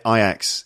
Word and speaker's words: Ajax: By the way, listeners, we do say Ajax in the UK. They probably have different pronunciations of that name. I think in France, I think they say Ajax. – Ajax. – I'm not sure. But anyway, we say Ajax.